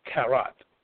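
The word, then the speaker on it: karat (כרת): is the word karat. Karat. You can spell it or you can transliterate it as K-A-R-A-T.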